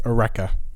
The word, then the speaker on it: Areca: So the first half is pronounced Areca.